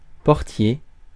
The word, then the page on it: portier: France; [pɔʁ.tje]